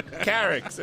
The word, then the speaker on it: Carix: Carix. It rhymes with Tarek.